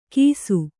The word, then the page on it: ಕಿಸು: kannada